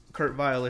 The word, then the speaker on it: Vile: And Kurt Vile is definitely pronounced with a V sound here for the American audience.